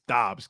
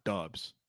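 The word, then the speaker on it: Doubs: It's Dobbs, not Dubs.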